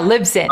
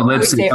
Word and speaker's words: Libsyn: We use Liz, how do you say it? Libsyn? Yeah, Libsyn. – Oh, Libsyn.